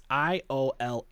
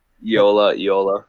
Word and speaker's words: Iola: Another name that's hard to pronounce. It's Iola. Yola? Eola? – Yola, Yola. – But this is the chick that was, you know, banging G-Man. So, Neneka. Just say Viola without a V, so it's Eola.